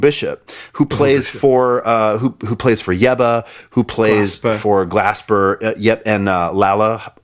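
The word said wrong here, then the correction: Lalah: am I pronouncing that correctly? – Layla Hathaway.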